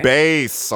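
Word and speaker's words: Anthony Bass: Bass. A lot of people pronounce it bass like the fish, but it's spelled the same as bass like the instrument.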